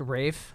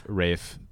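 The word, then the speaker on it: Raf: Number three for me was a collection by a guy named Raph Simmons. – It's pronounced Rafe. – Rafe Simons.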